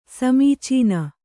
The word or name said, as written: ಸಮೀಚೀನ